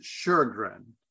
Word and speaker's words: Sjögren: Well, so I pronounce it Sjögren, like S-H-U-R-G-R-N, because there's an umlaut in over the O in Swedish. I've got a Finnish father. It gives it an R sound.